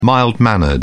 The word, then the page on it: mild-mannered: British English